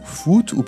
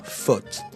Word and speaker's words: foot: Comment prononcez-vous le mot qui s'écrit F-O-O-T ? Plutôt avec le son OU, foot, ou plutôt avec le son O, faute ?